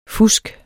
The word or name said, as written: fusk